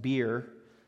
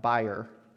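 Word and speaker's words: bier: They had, which I found out this week is pronounced beer, not buyer. I always pronounced it buyer, but it's apparently pronounced a beer.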